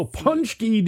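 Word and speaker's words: pączki: It's also Punchki Day, and it doesn't look like it's spelled.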